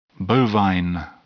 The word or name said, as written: bovine